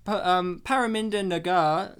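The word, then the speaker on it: Parminder Nagra: Paraminda Nagar, that's how it's pronounced too. I made sure I watched a YouTube video before.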